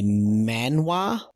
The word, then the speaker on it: manhwa: You can probably kill me on the pronunciation of that, but manga is MANGA. Anytime I look up Solo Leveling and where to read, they only say manhwa, which is MANWA.